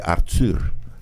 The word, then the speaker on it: Arthur: Se dice Arthur, en francés.